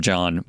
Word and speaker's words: Jahn: When Pollard goes in, we can call him John, but it's John spelled like Jan, like J-A-H-N.